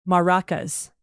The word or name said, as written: maracas